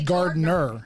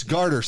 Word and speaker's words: garter: A lot of people say gardener. – Gardener, but it's garter snake, garter. – Right? – Garter. – Garter. Yeah, yeah, like a garter belt.